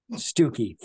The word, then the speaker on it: Stucki: Stucki. Think beef stew and a key in a door.